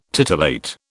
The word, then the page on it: titillate: British English